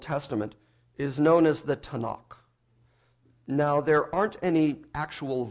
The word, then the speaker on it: Tanakh: would be a T and an N and a K. And then you fill in the vowel sounds, you get Tanakh.